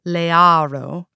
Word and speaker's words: Liero: I gleaned that in Godard's version, Lear was an American mobster named Don Liero, which he pronounced Learo, and so it followed that his youngest daughter, Cordelia, should be American as well.